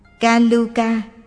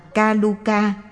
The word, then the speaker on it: Ca Lưu Ca: Ca Lưu Ca, cũng đọc là Ca Lu Ca hay Ca La Ca.